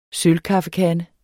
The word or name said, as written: KAFFEKANDE